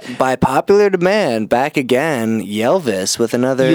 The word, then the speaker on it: Ylvis: It's pronounced Ylvis. – Ylvis.